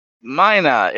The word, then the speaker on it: Mina: Mina is a correct way to pronounce it in my part of the country.